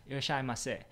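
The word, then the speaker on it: Irishimase (いらっしゃいませ): Am I pronouncing that right? Irishimase. Yeah, Irishimase.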